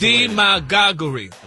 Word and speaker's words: demagoguery: You got D-E, M-A-G-A-H-G-U-H, re. – Well, that's demagoguery. – That's the way the dictionary broke it down. – The pronunciation, like emphasis is on the D.